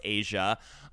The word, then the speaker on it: Eja: actually spelled E-J-A, but it's pronounced Asia, which I do think is a beautiful name.